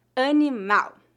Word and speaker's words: animal: Observe que a sílaba tônica de palavras terminadas em L é no final. Animal. Lembre-se de que a letra L é pronunciada como um U fraco em final de sílabas ou final de palavras.